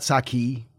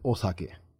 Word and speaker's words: sake: It's actually sake. It's not sake. Uh-huh. That's how us Americans say it, but it's sake. And if you're in Japan, you would call it osake with an O. First, like a lot of things, it's part of the language.